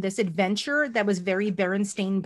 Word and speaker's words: Berenstain: It's spelled Stain, S-T-A-I-N. That's how it always was spelled growing up. I've always said Berenstain.